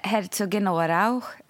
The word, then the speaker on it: Herzogenaurach: Men det heter Herzogenaurach, fast man ska ha någon liten sån här bra... Arg på slutet som jag inte riktigt fått till.